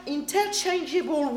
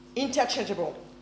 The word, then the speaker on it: interchangeable: So, in many, many passages of the Bible, the Holy Spirit is referred as God or in inter, inter oh, let me pronounce that properly inter, interchangeable ways. Does it make sense? Interchangeable. Interchangeable. See, that's my accent sometimes. Interchangeable. Interchangeable. that's the one. The accentuation is the right place there.